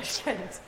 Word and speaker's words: Tashkent: Tashkent. – Tashkent.